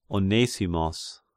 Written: Onesimos

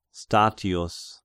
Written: Statius

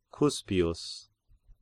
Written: Cuspius

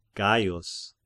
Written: Gaius

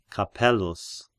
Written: Capellus

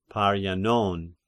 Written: Parians

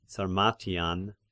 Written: Sarmartian

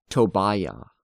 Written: Tobiah

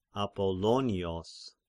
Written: Apollonios

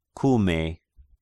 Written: Kume

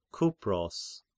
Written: Kypros

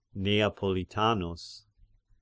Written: Neapolitanus

